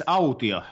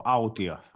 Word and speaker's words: Altio: It's Altio, so Altio. Almost like audio, but with a T, so Altio.